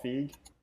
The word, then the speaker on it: Feig: Paul Feig, am I saying that right? – Yeah, I'm thinking of someone else.